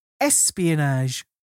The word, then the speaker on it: ESPionage: And when I say espionage, this is a hard thing to get across in an audio form, but it's spelled capital letters ESP lowercase ionage. So it's ESP Ionage.